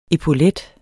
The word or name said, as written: epaulet